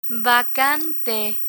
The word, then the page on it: vacante: Spanish